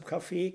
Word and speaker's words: Kaffee: Das Wort Kaffee spricht er selbstverständlich mit Betonung auf dem zweiten Vokal aus.